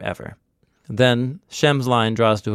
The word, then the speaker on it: Eber: The word Hebrew in English pronunciation comes from named Ever. You'll see Ever in the Bible. E-B-E-R. Hebrew. Hever. Heveru.